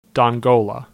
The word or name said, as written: Dongola